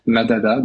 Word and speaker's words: Madada: Et si vous voulez vous simplifier la vie, je vous invite même à utiliser notre plateforme associative et citoyenne, Madada, donc c'est vraiment comme ça se prononce, M-A-D-A-D-A,